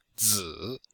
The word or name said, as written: zi3